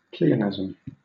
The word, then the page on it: pleonasm: British English